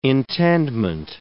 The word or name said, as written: intendment